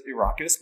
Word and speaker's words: Arrakis: Frank Herbert says Arrakis because it is the landscape is based on Iraq, the country.